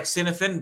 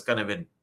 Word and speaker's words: Cynefin: The spelling is C-Y-N-E-F-I-N. Sounds like Cinefin, but pronounced as Canavan.